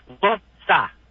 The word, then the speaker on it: Worcester: Yeah, two syllables. Worcester.